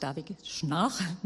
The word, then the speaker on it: Schnarch: Und da habe ich so eben ein Interview meines Lieblingstherapeuten, was Sexualität angeht, von David Snark mitgebracht. David Schnarch wird ausgesprochen.